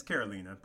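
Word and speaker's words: Karolína: Now, once again, here's another example of why these type of siblings didn't make our top five, because although Carolina has, or is it Carolina or Carolina? – It's Carolina, but that's okay. – It's Carolina. Okay.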